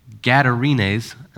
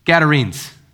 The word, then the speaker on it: Gadarenes: When he, Jesus, arrived at the other side in the region of the Gadarenes. I'm saying that wrong, Dale. Tell me what it is. Gadarenes.